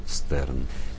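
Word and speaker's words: Stern: Эта драма обернулась позднее в литературную форму, благодаря Марио Ригоне-Стерну, которого обычно тут зовут просто Марио Стерн. Писатель родом из Северной Италии, из немецкоязычного анклава. И правильнее, вероятно, его фамилию произносить как Штерн, но будем следовать установившейся традиции и называть его Стерном.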